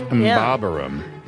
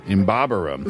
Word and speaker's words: Mbabaram: This is purely a coincidence, and there is no relation between English and Mbarabum. That's hard to pronounce. – Mbabaram. – Mbabaram.